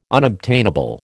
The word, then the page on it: unobtainable: American English